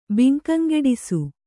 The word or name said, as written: ಬಿಂಕಂಗೆಡಿಸು